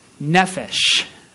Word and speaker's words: Nephesh: I'm going to have you pronounce it after me. Nephesh. You say it.